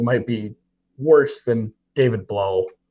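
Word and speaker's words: Blough: I think technically it's Blau. It rhymes with plow, as our Blau.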